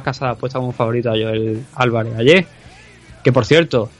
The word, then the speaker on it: Joel: Que hasta Dan Hardy tuvo que salir a pronunciar Joel, que es como se pronuncia.